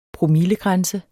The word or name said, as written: promillegrænse